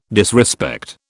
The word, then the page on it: disrespect: British English